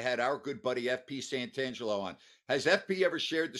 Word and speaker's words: Santangelo: Instead of Santangelo, he was calling him Santangelo without the T.